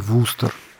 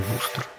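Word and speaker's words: Worcester: А посередине между Хартфордом и Бостоном расположен этот самый Вустер, который пишется Ворчестер, и ни за что ты не догадаешься, что читается он Вустер.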